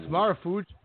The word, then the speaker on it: Marufuji: But, you know, Miyuchi Marufuji. – Yes, Marufuji. Awesome. That's a great pick. Pro Wrestling Noah. One of their aces. Been for a long time. Great pick. Miyuchi Marufuji.